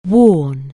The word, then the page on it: worn: anglais